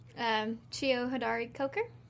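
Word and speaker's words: Cheo Hodari Coker: Chio Hadari Coker. Did I say that right? – Good for...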